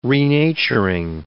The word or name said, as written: renaturing